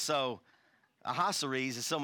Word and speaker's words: Ahasuerus: So we'll pronounce his name Ahasuerus, and I need a place to sleep tonight.